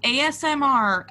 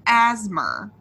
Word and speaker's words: ASMR: The show Criminal Minds on CBS aired an episode where they pronounced ASMR as asthma.